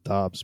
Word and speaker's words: Dobbs: He literally said it's pronounced Dobbs, but he doesn't care how you say it.